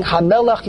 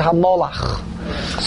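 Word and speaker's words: Hamelech (המלך): Instead of saying Hamelech, you say Hamolach.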